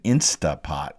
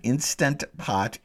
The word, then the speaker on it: Instant Pot: or as they mispronounce it, an Instapot. It's an Instant Pot. An Instant Pot is a brand.